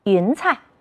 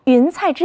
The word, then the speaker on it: 云彩: 这个词你读对了吗？云彩。彩色的彩在这里要读成轻声。再跟老师读一遍。云彩。雄鹰飞翔在云彩之间，是一种怎样的场景呀？